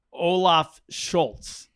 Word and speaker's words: Scholz: One of his main competitor is the Social Democratic Party, Olaf Scholz. Am I saying that right? He's the leader of that party. – Mm-hmm. Yeah. – There you go. – Saying it right.